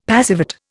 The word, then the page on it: passivate: Indian